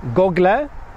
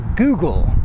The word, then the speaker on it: Google: I hear a lot of Polish people pronounce it either Google or Google. But the last two letters are not pronounced le, it's just Google. Say Google, never Google or Google.